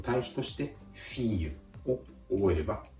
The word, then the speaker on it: fille: 発音自体はフィーユ、フィーユと、まあカタカナ発音でも良いんですけれども、実は綴りが FILLE L の発音を「ゆ」と発音するケースがフランス語ではしばしばあります